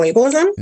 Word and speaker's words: ableism: Medical ableism? – No. Why can't I say A-B-L-E-I-S? What is that? That's ableism.